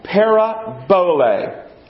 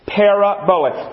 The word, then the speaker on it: parabole: And the Greek word is pronounced parabole. Parabole. Let's all say that together.